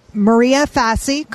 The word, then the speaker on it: Maria Fassi: So let's scooch over and let in Maria Fassi, correct? Did I say that right? Okay.